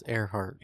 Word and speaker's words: Earhart: I keep on saying her name wrong. – I know. – Earhart. – It's Earhart.